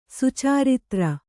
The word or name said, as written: ಸುಚರಿತ್ರ